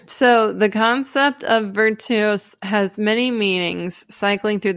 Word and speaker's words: virtus: I like virtus. I like the Beaumont pronunciation. Cool. But it's virtus.